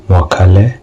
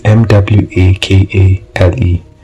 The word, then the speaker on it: Mwakale: It's two underscores Mwakale W. Wait. I can't even pronounce my own name. No, it's M W A K A L E.